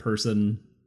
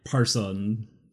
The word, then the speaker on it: Persson: And then during the making of their first demo tape, we would pronounce it person. I tried to find a actual pronunciation. – Let me see the... – In P-E-R-S-S-O-N. – Person? – Yeah, I heard, like, Parson.